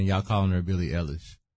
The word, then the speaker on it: Eilish: But I got to pop my collar a little bit because I did call out the fact that Billie Eilish was going to sweep up.